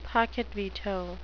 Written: pocket veto

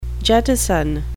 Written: jettison

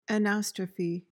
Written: anastrophe

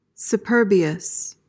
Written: superbious

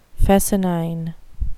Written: fescennine

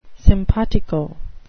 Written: simpatico

